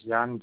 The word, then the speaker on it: Jan: Last name is Kruger, K-R-U-G-E-R. – Matt? – First name is Jan, J-A-N.